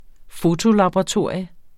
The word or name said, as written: fotolaboratorium